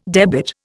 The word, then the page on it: debit: Indian